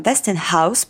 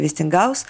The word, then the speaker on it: Вестингаус: Вестингаус, по-английски там Вестингаус, причем хаус пишется прям как дом, а по-русски это Вестингаус, выкупил у Теслы более 40 патентов и заплатил в среднем по 25 тысяч долларов за каждый.